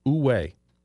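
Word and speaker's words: wu wei: and it's about this 2,000-year-old Chinese concept called Uwei. Now, it's spelled W-U space W-E-I, but it's pronounced Uwei, which translates to non-action or effortless action.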